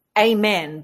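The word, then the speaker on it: Aimenn: And his name is, I presume you pronounce it Amen, but it's not spelt like Amen. It's A-I-M-E-N-N.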